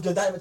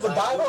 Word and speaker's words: Godiva: Is it Godiva or Godiva? – Godiva. – Oh yeah, it's Ryu. – She brought these Godiva chocolates. Godiva. – Godiva. – Godiva chocolates. Godiva. – Godiva. – No, that's how Americans say it.